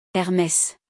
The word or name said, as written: Hermès